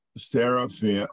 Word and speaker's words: seraphim: Am I saying that right? Seraphim? – That's it.